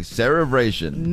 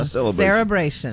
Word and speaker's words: cerebration: Yeah, now you said it correctly. Cerebration. – Never heard of it. – It's not an L. It's not a celebration.